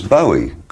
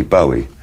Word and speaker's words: Bowie: I always thought it was Bowie. I thought, well, it's a Scottish name, must be Bowie. But nobody in Scotland pronounces it like that.